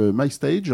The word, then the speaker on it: MyStage: Ok, donc l'appli MyStage, donc on la prononce bien à l'anglaise.